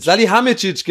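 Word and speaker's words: Salihamidžić: Also, Hasan sag mal, wie der heißt. – Salihamidzic, genau. So, deswegen kam ich gerade durcheinander. Ich habe es falsch gesagt.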